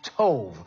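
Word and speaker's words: tov (טוב): And that word good is actually tov, T-O-W-B. It's pronounced tov, which means for your benefit.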